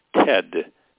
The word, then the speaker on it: Ted: My last name is Keyes, K-E-Y-E-S. My first name is Ted, T-E-D.